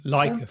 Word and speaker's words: Laika: And I always mispronounce their name, but Laika, Studio Laika, right? – Laika Films. – Laika, that's Laika. Sorry.